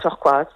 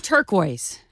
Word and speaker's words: turquoise: I've always said turquoise. – That's right. – Okay, so he's pronouncing it in the wrong language.